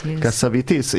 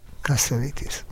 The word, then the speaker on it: Cassavetes: Azt mondtad, hogy te tudod, hogy kell Cassavetes nevét kiejteni. – Cassavetes. – Igen, én mindig Cassavetesnek mondtam. – Én is Cassavetesnek mondtam. – Igen, egy amerikai barátnőmtől hallottam. – Ja, kiejtette, és így kell.